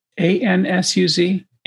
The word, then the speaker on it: Ansuz: A N S U Z.